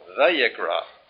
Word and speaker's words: Vayikra: The Hebrew does not have a V, but so many scholars were Jewish and German, and the Jewish scholars lived in Germany, and German has no W, so they pronounce the wow as a V. And so in Israel today, because of German influence, the wow is a V. So some will call it Vayikra, but I think that probably better would be Vayikra.